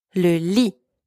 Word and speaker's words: le lit: Careful here, don't pronounce the T at the end. Le lit.